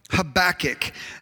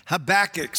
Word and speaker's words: Habakkuk: If you want to, when I hear it, I think maybe like when a cat is like hacking up a hairball. Habakkuk. So everybody say Habakkuk one more time. Habakkuk.